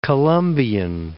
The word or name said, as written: Colombian